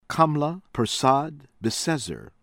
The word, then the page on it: Kamla Persad Bissessar: American English